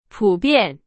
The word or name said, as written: 普遍